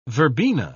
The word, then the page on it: verbena: British English